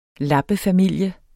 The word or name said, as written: lappefamilie